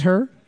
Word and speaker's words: Herre: We're looking for H-E-R-R-E. H-E-R-R-E. No one... getting it. Oh, yes. – Oh, no. – Feels good. Everyone thinking it's H-U-R-R. – Yeah. – No, H-E-R-R-E. That's how you pronounce her, just so you know.